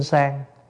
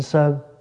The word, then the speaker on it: sơn: Thật ra là linh sơn. Nhưng mà tại vì chữ sơn có hai cách đọc. Một là đọc sơn, hai là đọc sang. Và để cho nó âm, nó vần với cái chữ sàng nhang. Ví dụ như đọc đúng ra cái anh đó, anh tên là Tiết Đinh Sơn, nhưng mà ngày xưa người ta đọc là Tiết Đinh Sang.